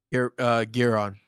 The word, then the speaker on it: Giron: Giron.